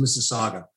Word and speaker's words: Mississauga: You pronounced it absolutely correctly. It's Mississauga.